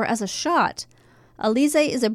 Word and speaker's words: Alizé: Alizé, is that how you say it? – Alizé is an award-winning blend of French vodka, because they're known for their vodka, and cognac, infused with exotic natural flavors.